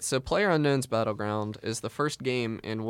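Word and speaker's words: PlayerUnknown's Battlegrounds: Did I say it right? – PlayerUnknown's Battleground.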